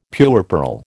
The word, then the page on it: puerperal: American English